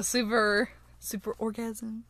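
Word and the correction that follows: Superorganism: No, it's Super Organism. – Oh, I had no idea that's how you pronounce it.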